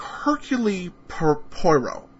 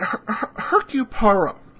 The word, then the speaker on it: Hercule Poirot: I have to look that up, because when I see it, it looks to me like Hercule Poirot, but that's not how it's pronounced. But Hercule Poirot,